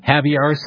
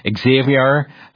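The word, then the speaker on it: Xavier Becerra: And some pronounce his name Javier, some Xavier, Becerra, doesn't matter.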